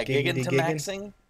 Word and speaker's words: Gigantamaxing: It's G-I-G-A-N, right? Gigan, I would say. And then T-A-M-A-X-I-N-G. Gigantamaxing.